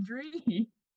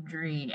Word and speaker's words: Dree: But then all of the Twitter friends that I made there, they would say it as Drea. And so I was a little bothered, so I changed the spelling, Dree.